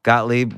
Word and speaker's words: Gottlieb: Gottlieb with me today on the podcast.